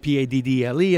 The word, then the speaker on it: padel: We used to call it PADDLE, and they yelled at me because the official name of the word is PADEL, just like basketball or volleyball. – Sure. – But it's phonetically, we say paddle.